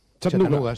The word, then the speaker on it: Chattanooga: ¿Si se pronuncia así en Chattanooga? – Chattanooga.